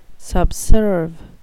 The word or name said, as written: subserve